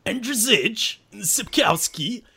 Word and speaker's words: Andrzej Sapkowski: So, basically, this guy, I'm going to pronounce his name the way it's supposed to be pronounced in the Polish tongue. It's pronounced Andrzej Sapkowski.